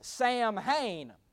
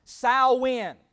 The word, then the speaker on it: Samhain: Samhain is the way it's pronounced. Now, if you read it or want to write it down, it's spelled Samhain. So it looks like it says Samhain. It is pronounced Samhain, okay?